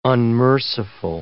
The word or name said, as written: unmerciful